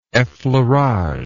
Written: effleurage

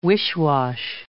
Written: wish-wash

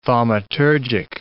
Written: thaumaturgic

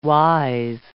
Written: wise

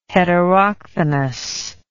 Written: heterochthonous

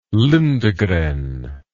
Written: lindegren

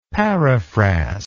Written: paraphrast

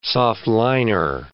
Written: soft-liner

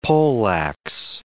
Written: poleax

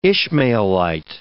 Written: Ishmaelite

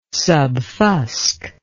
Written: subfusc

